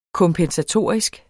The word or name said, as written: kompensatorisk